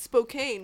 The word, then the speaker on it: Spokane: Spokane, police, we come one at a time here in Spokane. We come, just one person, we look for four criminals at a time. – Ooh, and left. You also pronounce it Spokane, which is cool.